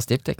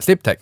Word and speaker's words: Sdiptech: Sen har vi Sdiptech, hur uttalar man det? – Ja, Sdiptech. – Sdiptech.